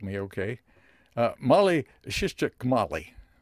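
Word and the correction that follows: Mihaly Csikszentmihalyi: Yeah, I've been told it's Mihai Csikszentmihalyi.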